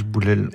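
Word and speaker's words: Bouhlel: Le personnage central, Mohamed, enfin le personnage central, le personnage atroce, Mohamed, alors je ne sais pas comment on prononce exactement son nom. – Hein. Bouel.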